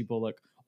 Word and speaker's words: Bullock: And if you want to throw maybe a Shedi Osman or a Reggie Bullock in there as well, or Bullock, excuse me. I realize I've been saying his name like that. I don't know why I was putting so much emphasis on the Ock at the end, but Block.